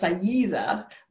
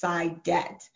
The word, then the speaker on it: Cydette: Oh, my first name, Cydette, is Arabic, and it's actually pronounced Sayida, but my dad is from Nigeria, and so the pronunciation is Cydette.